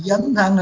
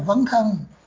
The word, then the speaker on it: dấn thân: Nhiều khi ông tưởng là tiếng Bắc phải đọc là V. Ông nói là vấn thân.